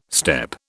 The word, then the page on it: step: British English